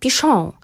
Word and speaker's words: Pichon: Dlatego, że jeżeli pisze się Chopin, prawda, przez CH pisze się Chopin, a czyta się Chopin, a właściwie Francuzi, ponieważ jest to francuskie nazwisko, Francuzi mówią Chopin, Fryderyk Chopin. Więc jeżeli sam Fryderyk wymyślił sobie swoją taką, no, takie swoje przezwisko, pseudonim taki artystyczny i nazwał się Jaśnie pan, pisze się to Pichon, no to czyta się Pichon.